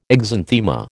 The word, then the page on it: exanthema: American English